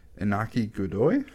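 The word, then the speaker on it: Iñaki Godoy: Inaki Godoy? – Yeah.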